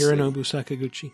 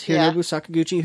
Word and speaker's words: Hironobu Sakaguchi: Hironobu Sakaguchi. – There it is. Hironobu Sakaguchi, who's he's the Final Fantasy dude.